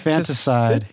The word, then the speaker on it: infanticide: Why do you think it keeps cutting to Medea, who is the one that um, committed uh, how do you pronounce that word? Infanticide? – Infantic- Infanticide. Yeah.